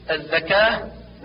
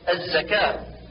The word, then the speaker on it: الزكاة: والعكس كالنطق بالزاي ذالا مثل الذكاء، والصواب الزكاة.